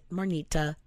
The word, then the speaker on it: Marnita: I said, Marnita, it's seven letters pronounced do you think my name's all like Marnita? Not that complicated. – And then you can read it. It looks exactly. It's not like there's no hidden letters.